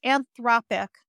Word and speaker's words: Anthropic: How do you say that? – Yeah, I'm going to give you the exact pronunciation. Wait, it is. – But they just got invested in by Google and they're building a ChatGPT competitor, right? – Yeah, it's Anthropic.